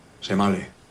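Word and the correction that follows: shemale: Shimei, se dice. Shimei.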